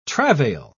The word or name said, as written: travail